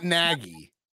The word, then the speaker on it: Nagy: Matt Nagy. – Nagy. That's right, dude. I've really got to get that out of my mind and say it how you say it. – It's not Nagy. – It's Nagy. – Nagy. Matt Nagy. Matt Nagy.